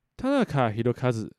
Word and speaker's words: 田中宏和: Tanaka Hirokazu 这个读音如果用汉字就是田中宏和这个名字哦。